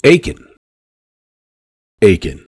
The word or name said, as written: Aitkin